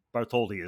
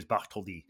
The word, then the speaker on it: Bartholdi: Frederick Auguste Bartholdi, or Bartholdi, as many people say. But the French pronunciation is Bartholdi.